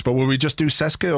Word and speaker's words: CSKA: It's pronounced CSKA. – CSKA. – But there's like a CSKA. – Oh, like a T in it.